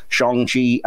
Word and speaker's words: Shang-Chi: I know it's an A, but I know it's got an A in it, but it is pronounced Shang-Chi.